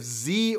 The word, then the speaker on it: Z: if you haven't seen The Lost City of Zed, that is one that is, it's a heavy drama adventure movie.